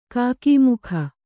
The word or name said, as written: ಕಕಮಕ